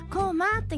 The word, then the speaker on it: 高麗: こま」